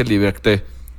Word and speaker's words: Liberté: dice Liberté. Son unos genios los franceses en cuanto a eso. – Esa pronunciación francesa la resbalaste y sí se notó, bien.